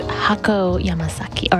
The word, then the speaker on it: Hako Yamasaki: And before that, you heard from Hatsuko Yamasaki off of her very first album, pronounced Hako Yamasaki, or excuse me, pronounced Tobimasu.